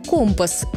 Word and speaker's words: компас: Компас с ударением на второй слог. В обычной речи мы чаще говорим «компас», и это тоже правильно. Но в профессиональной среде моряков закрепился другой вариант – «компас».